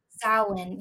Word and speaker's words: Samhain: How do you say it? The actual name for All Hallows Eve. – Samhain. – Yes.